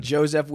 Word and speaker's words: Jozef: Jozef Chen. – Ethan Krellenston. You're watching The Simple Man Podcast. Guys, we have a very special guest today, Jozef Chen. – Jozef with a Z.